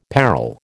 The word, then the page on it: peril: American English